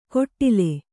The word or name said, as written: ಕೊಟ್ಟಿಲೆ